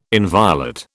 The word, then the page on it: inviolate: British English